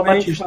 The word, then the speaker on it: Bautista: Bautista, mas eu descobri que se fala Batista. Fala, fala ah, Batista.